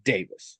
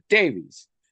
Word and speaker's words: Davies: The name is Welsh, and it is traditionally pronounced Davis. Some people pronounce it Davies.